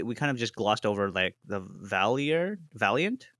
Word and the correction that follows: Valorant: One day you're going to learn how to say it. – How do I say it? – Valorant. – Valiant. Anyways, no, so what? – It's Valiant, right? – No, there's an R. – Valorant. – Yes.